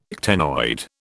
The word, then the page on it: ctenoid: British English